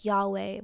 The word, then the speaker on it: Yahweh: God's name is almost always translated Lord in the English Bible, but the Hebrew would be pronounced something like Yahweh, which is what we just went over.